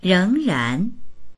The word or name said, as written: reng2ran2